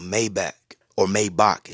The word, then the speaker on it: Maybach: Don't be going back to the hood in your freaking Rolls Royce or Maybach, excuse me, as it's pronounced.